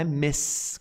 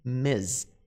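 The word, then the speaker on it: Ms.: No entanto, gente, tem uma forma que talvez seja a mais garantida de todas, que é essa abreviação aqui, que a pronúncia não é Miss, com som de S, mas sim Miss, Miss, Miss, com som de Z.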